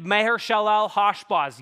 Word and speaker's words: Maher-Shalal-Hash-Baz: But this child would be named Maher-Shalal-Hash-Baz. Yes, that's how you pronounce it.